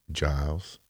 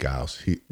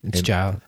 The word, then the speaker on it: Giles: I like Lachlan Giles, but did I say it wrong? – Yeah, you said Giles. – Giles. – And the only reason I know it's Giles